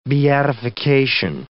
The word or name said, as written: beatification